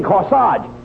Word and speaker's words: corsage: You mean corsage. A-G-E is pronounced arge, as in corsage and garage.